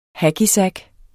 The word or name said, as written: hackysack